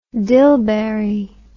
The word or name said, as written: dilberry